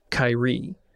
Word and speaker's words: Seer: The thing that I did in the original Morning Glory campaign was any character who was from Seer actually pronounced it Kairi, which is a thing that, like, Jim came up with because he was playing the character from there.